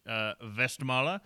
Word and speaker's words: Westmalle: So we have a pronunciation, Westmalle, or colloquially Westmall. Westie, if you're from Boston.